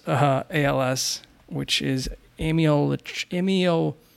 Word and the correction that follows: amyotrophic lateral sclerosis: I always screw that one. Amyotrophic lateral sclerosis.